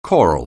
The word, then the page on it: choral: anglais